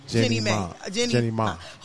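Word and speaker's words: Jeannie Mai: Jenny Ma. – Who are we going to do first? – Let's do Jenny Ma.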